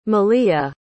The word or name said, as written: malia